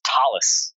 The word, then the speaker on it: Thales: It's Thales, ah.